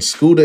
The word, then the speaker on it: Scooda: When you hear the word Scooda, because Scooda is not a new nickname, but when you hear someone say Scooda, you don't really hear the scooter. You don't hear the T-E-R. I try to, you know, spell it how it's pronounced, S-C-O-O-D-A, but going to the website will be the best way.